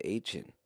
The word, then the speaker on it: Achane: Why do you keep saying Achane? – Because that's how he pronounces his name, sure.